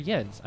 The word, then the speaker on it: Jens: So the last song you heard was Jens Lekman, or Jens. Does he pronounce it Jens or Jens? I mean, what the fuck? He's Swedish. It should be Jens.